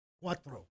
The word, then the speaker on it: cuatro: I'm still laughing at your pronunciation of cuatro. – Cuatro. – Roll those R's.